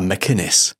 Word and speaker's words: MacInnes: MacInnes.